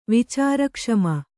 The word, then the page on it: ವಿಚಾರಕ್ಷಮ: vicāra kṣama